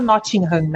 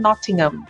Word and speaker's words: Nottingham: Inclusive, essas coisas de diferença de língua, na Inglaterra, eles não pronunciam o H de Notting. Tipo, a gente sempre fala Nottingham, né? Mas lá eles dizem Nottingham. Aí, esse final de semana, eu tava conversando com alguns vizinhos e ele perguntou onde eu tinha morado na Inglaterra.